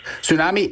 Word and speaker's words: tsunami: Tsunami. – Tsunami is correct. You got that silent letter. T-S-U-N-A-M-I. Tsunami.